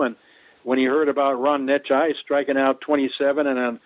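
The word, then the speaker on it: Necciai: Well, the way you pronounce his last name, it's Ron Necci. – Ron Necci. – Right. It's N-E-C-C-I-A-I.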